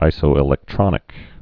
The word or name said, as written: isoelectronic